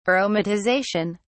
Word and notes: aromatization: British English

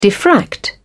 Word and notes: diffract: British English

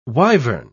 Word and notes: wyvern: British English

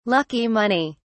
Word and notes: lucky money: British English

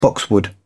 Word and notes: boxwood: British English

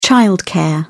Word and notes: childcare: British English

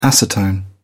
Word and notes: acetone: British English

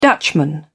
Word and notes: Dutchman: British English

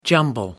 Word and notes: jumble: American English

